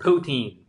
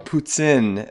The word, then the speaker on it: poutine: And eat poutine. No, I said it wrong again. – Oh, poutine. – That's it. There we go.